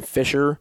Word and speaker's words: Fischer: Obviously, the C is silent, so a lot of people think it's spelled F-I-S-H-E-R, but it's really spelled F-I-S-C-H-E-R.